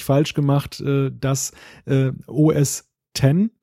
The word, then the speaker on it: OS X: Ich sage immer OS X und es ist völlig richtig, weil er schreibt OS X, das X steht eben für römisch 10 und das heißt OS 10, weil es ja als die Nachfolge von Mac OS 9 damals war.